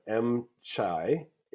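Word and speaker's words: Em Trai: Okay, so that soup is from a place called M Chai in it's in Metairie now.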